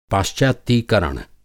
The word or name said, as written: पाश्चात्यीकरण